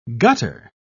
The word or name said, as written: gutter